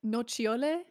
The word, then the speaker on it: Nocciole: Nocciole? – Yeah.